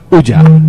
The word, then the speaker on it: Ouya: Lo que se pronuncia Ouya.